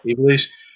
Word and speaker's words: Ivelisse: Ivelisse.